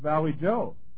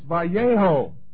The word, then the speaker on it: Vallejo: It's Valley Joe. She said, well, it's no big deal, but it's Vallejo. He said, it is a big deal. You say it right.